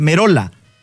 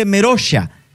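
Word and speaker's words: Merolla: Porque la pronunciación del apellido de Lucas en italiano, porque el apellido es italiano, es Merola. Pero el jugador dice Meroya. Entonces, si traemos No, pero eso puede ser argentino.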